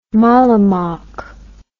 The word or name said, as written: mollemock